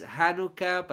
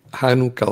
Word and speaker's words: Hanukkah: Este tema es muy interesante porque resulta que es uno de los que la gente más cuestiona respecto a si deberíamos celebrar Hanukkah o no, o Hanukkah. Estábamos hablando con Zvi cuál es la pronunciación adecuada. En español se conoce más como Hanukkah, pero entiendo que debiera ser Hanukkah.